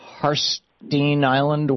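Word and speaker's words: Harstine Island: Patty in Harstein Island, Washington, listening to KBCS. Am I saying that right, Patty? – Well, you surely did.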